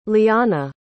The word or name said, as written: lianna